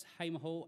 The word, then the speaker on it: Hamer Hall: So Melbourne date, we're at Hamer Hall, pronunciation correct? Yes, Hamer Hall, April the 16th, and we've got a bit of a different lineup.